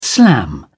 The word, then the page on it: slam: British English